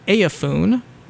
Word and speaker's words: EIAFUAWN: Anyway, kind of continuing on in a similar vein to that sound, we have a band called Eiafun, which is spelled E-I-A-F-U-A-W-N, and is short for Everything Is All F'd Up and Whatnot.